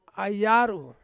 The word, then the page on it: ai yaru: Ansus